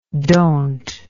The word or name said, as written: dont